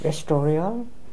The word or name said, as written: restorial